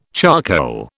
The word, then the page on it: charcoal: British English